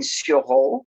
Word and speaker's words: sureau: Sureau. With it's a play on words because the French way is spelled S-U-R-E-A-U and is pronounced Sureau. So I decided to put it as the name is Sureau, S-U-R with a capital O.